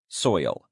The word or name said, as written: soil